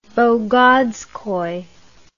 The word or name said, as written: bagazkoy